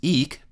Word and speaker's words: eke: It's pronounced eke because, in modern English, that final E is silent and is there to indicate that the first E is long.